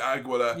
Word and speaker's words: Aguila: Well, I get the thick end of the wedge every time I mispronounce something, and I've been told that it's Aguila, and Howard Finkel said Aguila. – Aguila. – So it's Aguila.